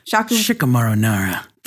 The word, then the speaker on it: Shikamaru Nara: playing Shikamaru Nara. Did I say that right, Tom? – Shikamaru Nara. – There it is.